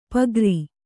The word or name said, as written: ಪೂಗಾರ